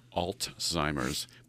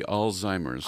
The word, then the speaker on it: Alzheimer's: I tend to put a T in it and call it Alzheimer's, but it's really Alzheimer's.